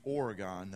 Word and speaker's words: Oregon: We could see all the way down into Oregon.